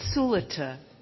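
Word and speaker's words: Sulata: Hi, I'm Sulita. And that was the name that you had trouble saying last night. It's S-U-L-A-T-A with the accent on the first syllable, Sulita.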